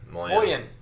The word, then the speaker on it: mullion: Mullion.